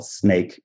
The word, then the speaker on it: Snaike: One of them is called Snake. It's spelled S-N-A-I-K-E. So a little play there, if you will, of AI.